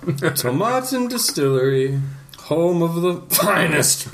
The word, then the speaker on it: Tomatin: Established in 1897, Tomatin, to rhyme with satin. – Which means it's Tomatin.